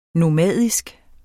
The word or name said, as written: nomadisk